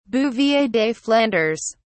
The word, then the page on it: bouvier des flandres: British English